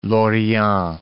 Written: Lorient

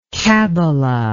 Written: kabala

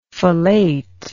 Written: fellatrix

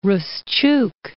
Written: Ruschuk

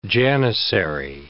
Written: janizary

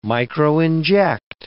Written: microinject

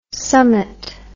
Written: summat

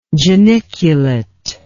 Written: geniculately

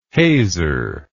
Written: hazer

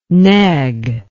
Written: neg

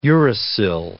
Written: uracil

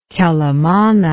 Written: Quelimane

